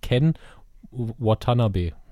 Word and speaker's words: Ken Watanabe: Ken Watanabe.